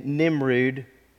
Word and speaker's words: Nimrud: The most sensible meaning was that there was a group of three cities, Nineveh, Khorsabad, and Nimrud, and that's how you pronounce it, I looked it up, which made up the Nineveh Triangle where Jonah was to preach.